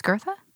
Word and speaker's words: Goethe: So it was very heavily influenced by Locke, Newton, and Kant, Goethe, who, by the way, I didn't know how to pronounce Goethe's name for a long time. I thought it was Goethe. I don't know if you. – Did. It's Goethe like Bertha. – You think it's Gertha? – Oh, I have no idea.